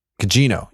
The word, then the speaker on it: Gugino: I think it's Gugino, but. – Gugino. Yeah. Hard G.